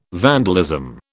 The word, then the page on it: vandalism: British English